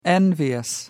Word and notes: envious: American English

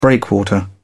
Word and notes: breakwater: British English

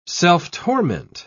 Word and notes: self-torment: British English

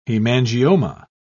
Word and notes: hemangioma: British English

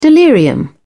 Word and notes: delirium: British English

